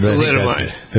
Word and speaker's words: thalidomide: I've never pronounced that right. – Thalidomide. – Thalidomide.